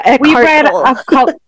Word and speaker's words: Eckhart Tolle: Today, well, the one we chose for this month is A New Earth by Eckhart Tolle. Am I saying that right? – Yeah, Eckhart Tolle.